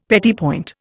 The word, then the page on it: petit point: Indian